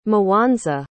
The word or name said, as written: mowanza